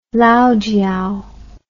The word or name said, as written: laojiao